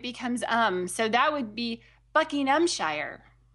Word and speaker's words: Buckinghamshire: So the emphasis is on the first syllable, Buckinghamshire. Buckinghamshire.